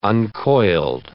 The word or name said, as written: uncoiled